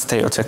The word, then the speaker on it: Ceuta: No, I mean, first of all, it's finally great to see here somebody who can pronounce Ceuta correctly, because we've had so many ways of doing it in recent times.